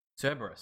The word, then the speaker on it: Cerberus: Cerebus. Yeah. Is it Cerebus? Am I saying that right? – Cerberus. – Cerberus. That's I'm right.